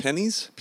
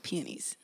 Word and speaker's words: peonies: Pennies? – Peonies. – Peonies? – Yes. – How do you spell that? – Yeah, be careful saying that one. – I know. P-E-O-N-I-E-S.